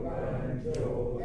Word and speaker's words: banjo: Someone in the kitchen is Dinah Strumming on the old banjo and singing. – ありがとうございます。で、バンジョーはバンがアクセントですかね。もしかしてちょっと発音は調べたので、アクセント調べてませんでしたが、多分そうです。バンジョーだと思う。今調べております。そうですね、初めの方にアクセントがあるから、 Strumming on the old banjo、そこがちゃんと強く、もう一回いきます。